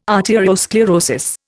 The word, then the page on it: arteriosclerosis: Indian